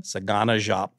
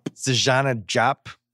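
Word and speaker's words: Saganà Diop: How do you say his name? – Sagana Jop. – Sagana Jop.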